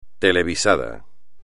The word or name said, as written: televisado